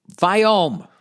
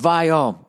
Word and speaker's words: Viome: S-I-M-P-L-I-S-A-F-E. All right. Viome. Pronounced Viome, like Steve Vai and O-M-E, home.